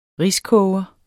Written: riskoger

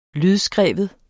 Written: lydskrive

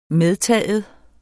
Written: medtaget

